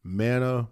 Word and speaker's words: Manna: But my YouTube channel is Manna Manoah 405. So Manna is spelled M-A-N-N-A, then space M-A-N-O-A-H, and the 405 is connected to Manoah.